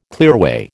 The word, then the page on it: clearway: American English